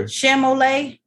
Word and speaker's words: Chalamet: Greta Gerwig and Timothy Lee Cabellet. Even though they said how do you pronounce that man's name? Timothy? – I don't know what you're trying to say. – Chalamet? – Oh, yeah.